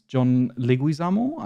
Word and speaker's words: John Leguizamo: And the same applies to Bob Hoskins, who plays Mario, and John Leguizamo. I don't know whether I mispronounced the name. I apologize. – No, you got it.